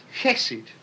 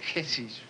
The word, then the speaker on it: chesed: Now, there's an Old Testament Hebrew word which is roughly the equivalent, and it's chesed, all right? That's how you say it, chesed, right?